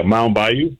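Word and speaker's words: Mound Bayou: It's Mount Bayou, right? Bayou? – Yeah, Mount Bayou.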